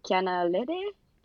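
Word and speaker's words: Kiana Lédé: Recently I've been listening to Kiana Lady. Really lately. I feel like I'm not pronouncing her name right. Kiana Lede.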